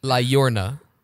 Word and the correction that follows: La Llorona: La Llorona.